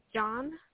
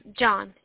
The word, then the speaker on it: John: So the first and last name is John Mancinelli. – And how do you spell that? – So the last name is being spelled Mancinelli. – Okay. First name again? – Is John, J-O-H-N.